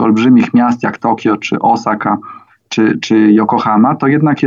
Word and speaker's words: Osaka: Ja teraz zwróciłam uwagę, jak pan zaakcentował, bo ja powiedziałam chyba Osaka, a pan. – Osaka. – Osaka, a ja powiedziałam Osaka. – No tak, ja już czasami nie do końca wymawiam może w, z poprawnym naszym polskim akcentem te nazwy, dlatego że widzę je i słyszę je już y, w, y, po japońsku. Czyli po japońsku jest Osaka.